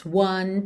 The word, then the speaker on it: one: Can touch us one time. One. Atenção também à pronúncia dessa palavra. One. Ok? Para quem assiste Star Wars, o nome do Obi-Wan Kenobi, Obi-Wan, Obi-Wan, é uma ótima dica.